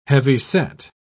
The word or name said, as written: heavyset